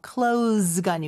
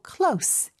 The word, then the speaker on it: close: close가 아니고 close. S로 꼭 발음을 해주셔야 가까운이라는 뜻의 표현이 됩니다.